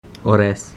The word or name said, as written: ores